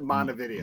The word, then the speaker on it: Montevideo: Well, that's like there's a city in Minnesota called Montevideo. – Oh. – They pronounce it Montevideo. – Montevideo, of course. Yeah, why wouldn't you? – Not Montevideo. You know, well, like God intended it to be.